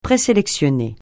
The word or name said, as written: présélectionner